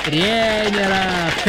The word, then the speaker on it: тренеров: Алло, тренеров, да, на Е первое. – Да, тренеров, тренеров.